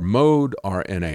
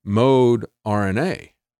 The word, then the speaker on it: Moderna: And that's the way they pronounced their company's name when they first put it out there. They pronounced it mode RNA. And then they changed it to call it Moderna.